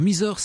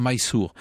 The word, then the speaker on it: Mysore: Alors Mysore, c'est en fait la prononciation française d'une graphie anglaise, il faudrait dire Mysore.